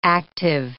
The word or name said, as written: active